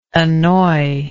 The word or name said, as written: annoyer